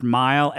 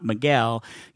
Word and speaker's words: Mighell: This is all I need so that people will finally know that my name is pronounced Mile and not Miguel.